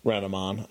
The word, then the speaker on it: Renamon: Because I know we're going to get angry emails about, no, you pronounced it wrong. – I've only seen it written. I've never actually watched Digimon. – Well, I know enough nerds. It's Renamon, apparently.